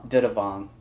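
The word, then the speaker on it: Dithavong: Yeah, it's Didavong. Yeah. It's Van Didavong, yeah, because the H is a little bit silent there, so.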